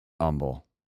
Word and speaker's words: Humble: First, the H is silent, but Humble.